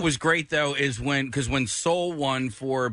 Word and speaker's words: Soul: Tracy Morgan pronounced it as Saul, right? He goes, and the winner is, and he goes, Saul. And then there was no reaction because there was no movie called Saul.